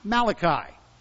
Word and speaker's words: Malachi: Well, I'm sorry, he's not the Malachi. He wasn't Italian. His name is pronounced Malachi.